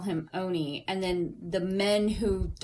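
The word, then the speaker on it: Owney: It's Oney, but I want to say Owen because...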